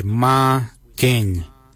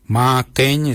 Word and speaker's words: marrgeny: The number 6 we say ma geng. Ma geng is spelled M-A-R-R-G-E-N-Y. Ma geng. For the number 7 we say ma kuju. Ma kuju is spelled M-A-R-R, then it's G-O-O-D-J-A-L.